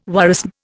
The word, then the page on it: worrisome: Indian